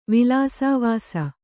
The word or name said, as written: ವಿಲಾಸವಾಸ